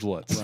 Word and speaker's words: Lutz: Yep, George Lutz. – Rhymes with butts.